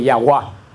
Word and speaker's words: Yahvé: La palabra Jehová viene del siglo XI o del siglo XII, que es una mala pronunciación de la Y de Yahuá. Porque en Alemania lo pusieron en una de las traducciones, cuando se estaba escribiendo la Biblia con la fonética alemana, en vez de poner con la Y, la pusieron con una J que suena como Y.